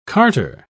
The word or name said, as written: carter